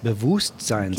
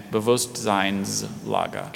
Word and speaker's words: Bewusstseinslager: Bewusstseinslager. – Can you please repeat both pronunciations? – Look at that word. – They're very slight variants. So, Bewusstseinslager and Bewusstseinslager.